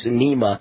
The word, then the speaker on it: Sinema: Sinema. Is that how you pronounce it? Sinema.